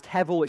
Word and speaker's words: hebel: The language the psalmist uses when he talks about, at the end of verse 5, each man's life is but a breath, that Hebrew word is pronounced hevel, it's spelled H-E-B-E-L, and it's exactly the same word that's used in Ecclesiastes.